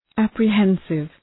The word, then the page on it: apprehensive: ,æprı’hensıv